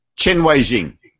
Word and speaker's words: Chen Weijing: He says, Why do modern defenders like Joo Se-hyuk and Chen Wen Zing, I've just got to get better at my pronunciation, why do these Chen Weijing. Sorry? – Chen Weijing.